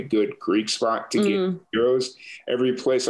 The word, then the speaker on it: gyros: So no, the correct one is gyros.